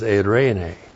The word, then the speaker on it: eirene: E-I-R, long E-N, long E, eirene. So the Greek equivalent of shalom is eirene.